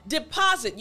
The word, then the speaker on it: deposit: So, deposit, you know that there's an S in this word. However, it doesn't have the typical S sound. So again, in the middle of the word, well, towards the end actually, we have this S, remember, deposit. You heard it that time, didn't you, right? It has kind of a Z sound.